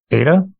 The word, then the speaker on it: Ada: Ada, did I pronounce that right? – Yes.